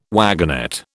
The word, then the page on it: wagonette: British English